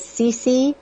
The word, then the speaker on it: Cece: My name is Cece. It's spelled C-E-C-E. And my last name is Doucette, D-O-U-C-E-T-T-E.